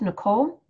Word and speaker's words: Nicole: So you can connect with me on Facebook on the Shape It Up page or on Instagram at Shape It Up with Nicole and my name is spelled N I C O L E.